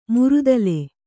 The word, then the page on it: ಮರ್ದಲ: mardala